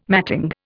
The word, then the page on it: matting: Indian